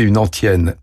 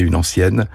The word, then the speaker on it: antienne: Autrement dit, il ne doit pas être prononcé, attention, comme ancienne. Et là, surprise, je me déjuge, il faut dire antienne. Donc, signalons pour conclure que dans gajure le groupe UR se prononce UR. On dira chanter une antienne et non pas chanter une ancienne.